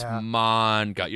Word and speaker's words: manga: It's manga. – It's manga. You're right. Manga. Like the A is long.